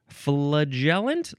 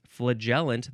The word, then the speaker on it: flagellant: It's flagellant. How do you say this? Flagellant, that's one of the ways. Or you can say flagellant. F-L-A-G-E-L-L-A-N-T.